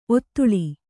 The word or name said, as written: ಔತಳ